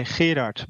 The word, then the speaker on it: Gerard: Yes, well, but the hard G in Dutch is pronounced as in Scottish loch. – Oh, okay. – So right. We would say Gerard, but I wouldn't wouldn't recommend you try.